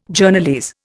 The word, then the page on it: Journalese: Indian